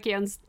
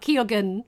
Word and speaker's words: Keoghan: And also, I'd like to put over people pronouncing Barry Keoghan's name correctly. And not Keoghan, which is what they said on the announcer, too.